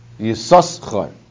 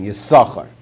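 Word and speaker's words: Yisaschar (יששכר): you should pronounce it with two sins. Yisaschar. Because you want you want to pronounce and, and publicize that's the name. The name is Yisaschar. In other words, you don't write it with one sin, you write it with two. But then subsequent times you would read it with with uh, pronouncing it once in Yisachar.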